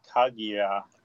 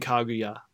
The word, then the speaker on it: Kaguya: Yeah, I think they actually pronounce it Kaguya. – I'm sorry. I should be saying Kaguya. I keep putting the ac- I I keep putting the stress on the wrong syllable.